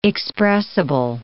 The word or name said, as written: expressible